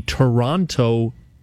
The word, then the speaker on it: Toronto: I said, you do realize that I'm a Toronto, I pronounced the second T.